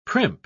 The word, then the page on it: primp: British English